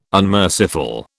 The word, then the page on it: unmerciful: British English